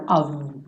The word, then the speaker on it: of: Todo ovo vai ser pronunciado ovo com V, né? Esse OF vai ser ovo toda vez, sem exceção, ok?